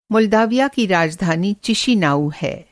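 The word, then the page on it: मॉल्डोवा: Urdu script مالڈووا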